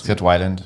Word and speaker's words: Weiland: Und der Sänger Scott, wie spricht man ihn eigentlich aus? – Scott Weiland.